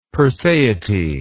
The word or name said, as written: perseity